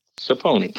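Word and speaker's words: Sapony: Saponi. – How do you spell that? – It's S-A-P-O-N-Y.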